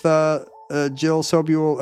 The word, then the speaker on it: Sobule: If you tax the rich, you don't get cool tribute albums with Jill Sobule.